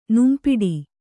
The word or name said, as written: ನುಂಪಿಡಿ